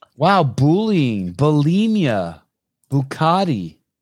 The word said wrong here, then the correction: Bugatti: Bugatti. – Bugatti.